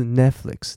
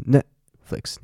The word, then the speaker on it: Netflix: 我上次看到一个老师他在教 Netflix 嘛，他就说 t 不发音，t 其实不是不发音，t 是要憋住气，它不是 Netflix，它是 Netflix，要用力。